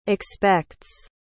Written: expects

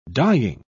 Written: dyeing

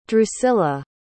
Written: drusilla

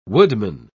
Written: woodman

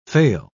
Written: faille

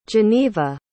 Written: Janeeva

